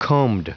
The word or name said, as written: combed